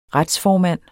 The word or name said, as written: retsformand